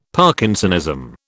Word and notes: Parkinsonism: British English

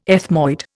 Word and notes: ethmoid: Indian

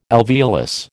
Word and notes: alveolus: American English

